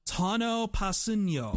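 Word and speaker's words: Tanoh Kpassagnon: But his name is pronounced Tano Passigno.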